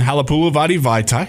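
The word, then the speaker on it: Halapoulivaati Vaitai: Yeah, so we got bad news on Halapula Vadi Vaitai. Hey, I said it right first time.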